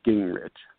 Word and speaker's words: Guengerich: But I usually suggest people not look at it because it's easier to pronounce if you don't look at it. It's Guengerich.